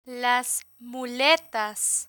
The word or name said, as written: las muletas